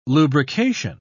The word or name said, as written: lubrication